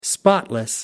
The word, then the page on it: spotless: American English